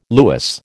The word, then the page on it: lewis: American English